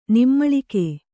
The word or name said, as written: ನಿಮ್ಮಳಿಕೆ